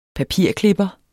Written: papirklipper

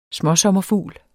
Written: småsommerfugl

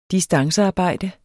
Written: distancearbejde

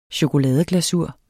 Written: chokoladeglasur

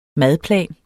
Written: madplan

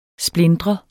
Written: splintre

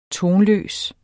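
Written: TONLØST

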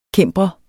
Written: cimbrer

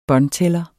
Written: båndtæller

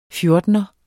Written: fjortener

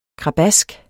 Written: krabask

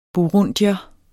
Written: burundier

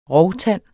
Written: rovtand

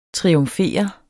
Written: TRIUMFERER

